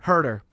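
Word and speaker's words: Sam Herder: Sam Heater, I don't think I pronounced it. – Herder. – Herder, pardon me, Sam.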